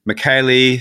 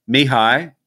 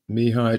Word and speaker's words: Mihaly: And why I guess this caught my eye, especially because we've spoken about this man, gentleman, before, and we've called him Mikhaili Csikszentmihalyi, which is not really a great pronunciation, but he passed away last week. But this is how the phonetic guide is Mihai Csikszentmihalyi. – Mihai Csikszentmihalyi. Yes.